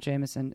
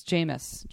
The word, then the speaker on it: Jameis: No, it's not Jameson. It's Jameis.